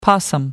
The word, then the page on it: possum: American English